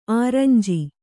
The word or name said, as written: ಅರಂಜಿ